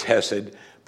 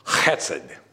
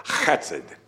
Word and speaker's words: hesed: Now, I'll say it as hesed, but it's not the correct pronunciation. It's hesed. You have to do that in the back of your throat, hesed.